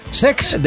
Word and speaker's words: Cesc: No lo he dicho mal, es Sex.